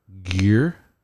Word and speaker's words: Gere: No, Pretty Woman, the movie, Richard Gere.